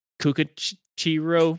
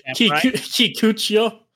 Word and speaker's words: Kikuchiyo: Kukuchiro from Kikuchiyo.